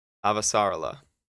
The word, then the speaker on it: Avasarala: Christian Avasarala. And that's how it's pronounced. No ifs, ands, or buts.